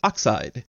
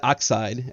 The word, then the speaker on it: ox-eyed: And Hera has a very interesting name, Buapis, and that means ox-eyed. Ox-eyed. I should say that properly. Not oxide, as in a molecule of oxygen, but having the eye of an ox.